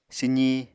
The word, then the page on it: swzi: apatani